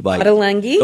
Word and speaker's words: Ottolenghi: Ottolenghi.